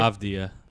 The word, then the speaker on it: Avdija: Uh, my next forward, I have Deni Avdija. Right? Wow. – Yeah. – Is that how you say it? I said that right, right? – Avdija.